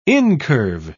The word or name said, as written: incurve